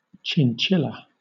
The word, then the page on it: chinchilla: British English